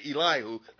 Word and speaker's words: Elihu: Did you notice that Elihu, sometimes I call him Elihu, I'm trying to say Elihu, that's the correct pronunciation.